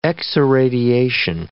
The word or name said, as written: x-irradiation